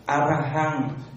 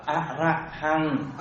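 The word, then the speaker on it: arahaṃ: À, cái chữ số 1 đọc là Arahan. Arahan. Không phải A ấy, chữ này đọc là Arahan.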